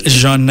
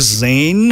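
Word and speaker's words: Zhané: It's Jeanne. – Jeanne. – Yeah, it's spelled Z-H-A-N-E. Ah. So a lot of people used to think it was Zane.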